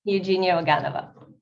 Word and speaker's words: Eugenia Oganova: Eugenia Oganova.